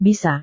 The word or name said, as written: bisa